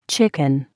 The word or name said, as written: chicken